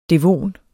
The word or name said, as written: devon